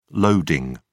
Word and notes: loading: British English

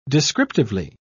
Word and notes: descriptively: British English

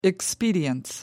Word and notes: expedience: American English

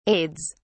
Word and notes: aides: British English

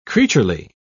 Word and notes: creaturely: British English

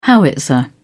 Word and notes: howitzer: British English

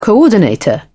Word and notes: coordinator: British English

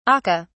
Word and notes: akka: British English

